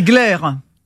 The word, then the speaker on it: Gleyre: C'est Glaire.